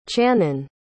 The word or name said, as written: chanan